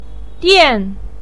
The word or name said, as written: dian4